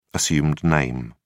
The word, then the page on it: assumed name: British English